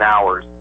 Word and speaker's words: hours: You don't pronounce the H in hours.